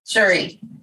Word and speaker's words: Cherie: She studied for the International Coaching Federation accreditation with Dr. Cherry Carter Scott. Or is it Cherie? – Cherie. – Okay. I've had people spell it that way in Cherry, and some say Cherie.